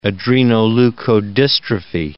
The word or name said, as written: adrenoleukodystrophy